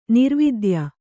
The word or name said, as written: ನಿರವದ್ಯೆ